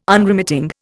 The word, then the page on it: Unremitting: Indian